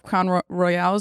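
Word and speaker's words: Crown Royal: So last night I brought on a bottle of Crown Royale. Is that how you say it? – Yeah.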